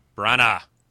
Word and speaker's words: Branagh: Branagh.